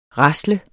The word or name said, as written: rasle